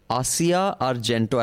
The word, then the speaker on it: Asia Argento: This story on Asia Argento.